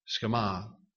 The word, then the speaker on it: Shema: It's spelled S-H-E-M-A, but it's pronounced Shema. Shema.